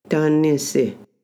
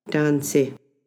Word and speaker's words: Tansi: When you see it written, however, you might notice that it would sound like this. – Tanisi. – But it is more correctly pronounced by fluent speakers as Tansi.